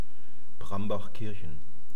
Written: prambachkirchen

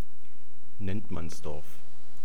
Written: nenntmannsdorf

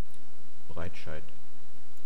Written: Breitscheid

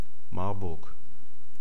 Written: marburg

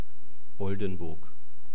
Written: oldenburg

